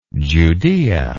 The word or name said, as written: Judaea